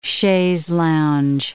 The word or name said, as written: chaise lounge